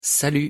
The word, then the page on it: salut: French